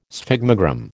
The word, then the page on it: sphygmogram: American English